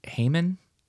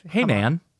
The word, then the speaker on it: Haman: I believe it's pronounced like Haman. – Haman? – Instead of Haman.